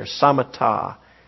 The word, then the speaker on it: samatha: I have it on your notes there, samatha. It's spelled samatha, but th is still pronounced as a ta sound.